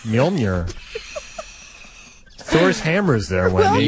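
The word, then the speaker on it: Mjölnir: Mjolnir. – Mjolnir. – There's a soft J in there, which makes the y sound.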